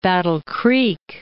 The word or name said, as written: Battle Creek